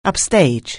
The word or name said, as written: upstage